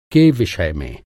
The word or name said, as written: विषय